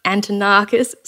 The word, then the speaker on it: Antonakis: John Antonart, Antonakis, sorry, um, insert really good pronunciation there, um, from Switzerland.